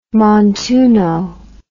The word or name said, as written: montuno